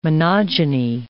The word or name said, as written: monogyny